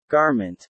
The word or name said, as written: garment